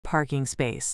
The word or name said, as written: parking space